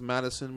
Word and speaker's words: Matthewson: It's pronounced Madison, but right. Spelled Matthewson.